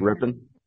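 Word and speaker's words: Rypien: Ripien. How do you say that name? – Ripien. – Is it Ripien? – Yep.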